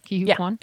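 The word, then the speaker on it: Ke Huy Quan: Of course, I really want Ki-Hoo Kwan. Is that how you say it though? Ki-Hoo Kwan? – It is.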